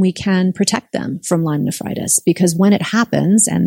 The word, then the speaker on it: Lyme nephritis: But it can lead to something called Lyme nephritis. Did I say that right? – Yeah, absolutely. Just kidney disease associated with those bacteria.